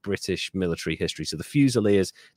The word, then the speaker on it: Fusiliers: Fusiliers. – Oh, Fusiliers, yes.